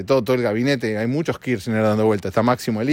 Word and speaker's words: Kirchner: Son los mismos que en Argentina no pueden pronunciar bien el apellido de la vicepresidenta. Fernández de Kirchner. Kirchner es. No es tan difícil. Pero muchos dicen Kirchner, Kirchner. Kirchner, entonces no lo pueden decir.